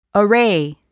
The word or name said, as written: array